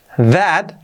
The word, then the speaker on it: that: That, lo mismo, la pronunciación con la th, que es una z sonora, that, y la a un poco así, that.